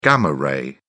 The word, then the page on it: gamma ray: British English